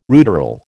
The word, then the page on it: ruderal: American English